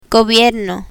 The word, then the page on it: gobierno: spanish